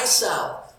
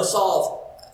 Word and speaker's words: salve: The eye salve, you know, salve. You pronounce it salve or salve? Salve. I need to say it more, not just read it. Salve. That makes so much more sense.